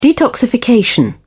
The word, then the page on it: detoxification: British English